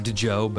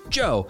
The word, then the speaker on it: Djo: He goes by Joe, but it's confusing because it's spelled D-J-O. So it looks like DeJo, but I believe it's pronounced Joe.